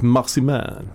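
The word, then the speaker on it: Marcimain: Ja, det är, det är det han Mikael Marsimain. Just det. Uttalar man hans namn så? Jag har alltid sagt Marsiman. Marsiman.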